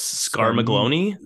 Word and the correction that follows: Scarmiglione: Scarmiglione. I think they all have Italian. I think they all have Italian names.